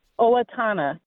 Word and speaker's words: Owatonna: Yes, Owatonna.